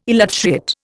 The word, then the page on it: elutriate: Indian